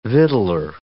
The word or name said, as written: victualler